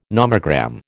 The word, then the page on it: nomogram: American English